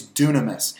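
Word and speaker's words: dunamis (δύναμις): And it says, with great power, and that word power in the Greek is dunamis. And dunamis is the same word that we use for the word dynamite. It's spelled D-Y-N-A-M-U-S, dunamis.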